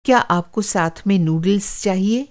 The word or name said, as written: नूडल्स